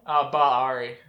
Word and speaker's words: Bar-Ari: Say it for me. – Bariari. – Bariari. Bariari is better.